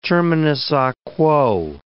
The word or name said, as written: terminus a quo